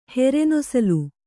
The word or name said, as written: ಹೆರೆನೊಸಲು